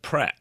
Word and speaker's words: Pret: We just call it Pret.